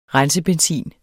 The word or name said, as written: rensebenzin